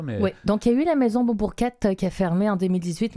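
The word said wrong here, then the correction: Monbourquette: Mais si on va quand même encore sur le site de la Maison Montbourquette, comme ça se prononce, oui.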